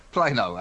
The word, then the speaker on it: Plano: Plano or Plano? No, Plano.